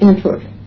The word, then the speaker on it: Antwerp: Is that how you pronounce it? Antwerp? – Antwerp. – Antwerp, I think you called it.